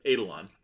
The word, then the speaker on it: Eidolon: Eidolon.